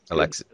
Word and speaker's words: Alexis: Alexis.